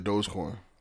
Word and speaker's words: Dogecoin: Yeah, Dogecoin.